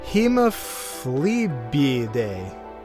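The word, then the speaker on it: Hemiphlebiidae: And then the family is Hemophlebiidae. There we go. There's the accent on the first eye and not the second eye that's right next to it.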